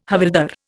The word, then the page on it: havildar: Indian